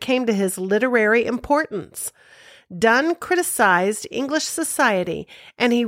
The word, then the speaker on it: Donne: His name is pronounced Donne, but it is spelled D-O-N-N-E.